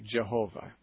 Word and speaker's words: Yahweh: And if you add the letters, if you add the vowels to YHWH, it would be pronounced something like Yahweh.